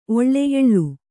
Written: ಒಳ್ಳೆ ಎಳ್ಳು

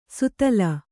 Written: ಸುತ್ತಲ್

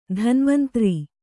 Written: ಧನ್ವಂತರಿ

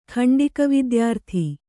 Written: ಖಂಡಿಕವಿದ್ಯಾರ್ಥಿ

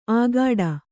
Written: ಆಗಾಡ

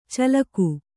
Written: ಚಾಲಾಕು

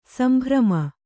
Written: ಸಂಭ್ರಮ

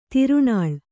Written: ತಿರುಣಾಳ್

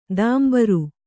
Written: ದಾಂಬರು

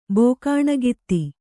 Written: ಬೋಕಾಣಗಿತ್ತಿ

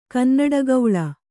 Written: ಕನ್ನಡಗೌಳ

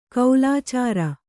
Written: ಕೋಲಚಾರಿ

